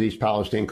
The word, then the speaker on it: East Palestine: By the way, 5 stars for pronouncing it correctly. As a Buckeye who grew up 30 miles from there, I'm very glad someone pronounces East Palestine correctly from the Ohio point of view.